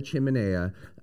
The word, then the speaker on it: chiminea: We have this chiminea outside, which people keep like they're mispronouncing chimney. It's called a chiminea.